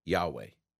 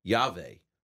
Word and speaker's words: Yahweh: The unpronounceable name of God, Yud-Heh-Vav-Heh, many scholars today say it's pronounced Yahweh. But we're not sure because that presumes the vowels we're putting in.